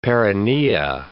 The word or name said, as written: perinea